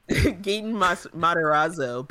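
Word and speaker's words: Gaten Matarazzo: Gaten Matarazzo, better known as Dustin on Stranger Things.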